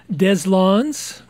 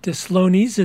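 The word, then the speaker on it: Deslondes: And we'd like to welcome from the Crescent City of New Orleans, Deslons, not Deslonies, as I pronounced it earlier, so I correct myself.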